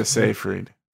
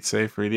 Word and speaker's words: Seyfried: Siegfried? – Amanda Seyfried. – Seyfried? Is that how you pronounce it? Seyfried, yeah.